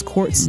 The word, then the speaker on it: qtz.: Her name is Quartz mm-hmm. dot. That's how it's pronounced, but it's just spelled Q T Z dot.